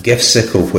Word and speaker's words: Gifsicle: And GIFsicle, which is, I think, made to rhyme with popsicle, as in the lollipop or whatever, is a command line GIF tool.